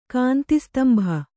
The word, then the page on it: ಕಾಂತಿಸ್ತಂಭ: Kannada